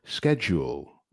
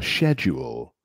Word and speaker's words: schedule: Así que tenemos algunos clásicos, por ejemplo, schedule o schedule. So, schedule, británico o británico, y schedule, americano. Pero bueno, los dos están bien, ¿no? Y se puede usar, pero simplemente se pronuncia diferente.